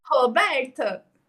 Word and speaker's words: Roberta: Roberta. – Então, o o, o nem é o, eu não consigo falar, é o fechado.